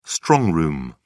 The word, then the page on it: strong room: British English